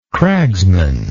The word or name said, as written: cragsman